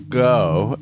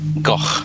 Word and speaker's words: Gogh: It is pronounced go in the United States, and it is pronounced go in the UK and the other educated parts of the world. – Yeah, the educated part. – You're going to have to pronounce this for me, okay? Go ahead and pronounce it for me, so I'll try to get it right. – It's Vincent Van Gogh. – You know, like you're trying to hawk up a hairball.